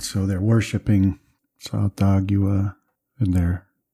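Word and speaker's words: Tsathoggua: When you heard that, those syllables being chanted, it was Sa-Thogua.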